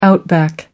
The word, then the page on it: outback: American English